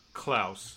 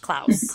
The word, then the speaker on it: Klaus: When spelled with a K, Klaus is never pronounced like pause. It is pronounced like house. Klaus. Klaus.